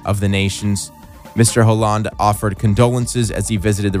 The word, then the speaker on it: Hollande: Yeah, I don't really know how to say the president's name. François Hollande. – Hollande. – Yeah, you're right.